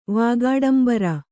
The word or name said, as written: ವಾಗಾಡಂಬರ